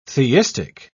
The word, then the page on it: theistic: British English